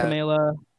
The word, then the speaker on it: Kamala: And that's the pronunciation, right? Kamala? – Uh-huh. – Kamala.